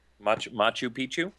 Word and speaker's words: Machu Picchu: Can you say it for me? – Machu Picchu? – Exactly. That's how you say it.